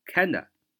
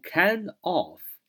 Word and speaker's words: kinda: 呃，外国人，我们所谓的 native speaker，就是说本土的这样的一个说话者呢，他常常会略读，读成 kinda 啊，kinda，kinda，kinda 啊，就不读 kind of 啊。